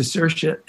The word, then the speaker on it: Saoirse: Like you're talking to her, to Saoirse, and she, which is how you pronounce her name, right?